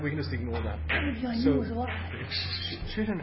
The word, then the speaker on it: chitin: It's spelled chitin, but it's pronounced chitin.